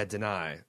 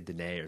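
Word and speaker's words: Danai: Yeah, Denai. Mm-hmm. I always want to say Denae or something.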